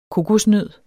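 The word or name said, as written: KOKOSNØD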